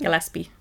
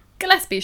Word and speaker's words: Gillespie: Can't pronounce that. Is that how you pronounce it? – Gillespie. – Gillespie, sure. Gillespie, potato, potato.